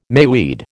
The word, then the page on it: mayweed: American English